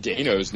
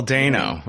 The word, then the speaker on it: Dano: I'm not sure about Dano's name. I just saw an interview with him where he said it rhymes with Drano, and he himself said it was Dano, and I was like, okay, finally.